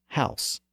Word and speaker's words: Haus: They're both pronounced as Haus. Again, they came from the same Germanic root word, which was Husan. In Standard English, the vowel evolved from Hus to Haus.